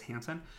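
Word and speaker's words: Hanssen: His name's kind of spelled like Hasten, but it's Hansen.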